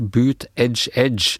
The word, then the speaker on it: Buttigieg: Uttales visstnok boot edge edge, bare så jeg slipper å gremmes mer mens jeg hører på podkasten deres, skriver hun. så boot edge edge, altså.